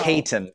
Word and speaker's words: Caton: Caton, which is spelled C-A-T-O-N.